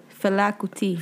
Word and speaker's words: Fela Kuti: Oh, so how do I pronounce it? – Fela Kuti. – Fela Kuti.